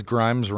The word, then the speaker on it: Grimes: Because Grimes rhymes with crimes.